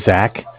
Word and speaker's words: Zach: So my name is Zach, spelled Z-A-C-H. Mm-hmm. And I am following up from Bank of Cardiff. Cardiff is spelled C-A-R as in car, D as in dog, I as in island, F-F as in Frank.